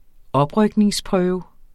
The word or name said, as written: oprykningsprøve